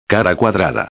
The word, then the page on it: cuadrada: espanhol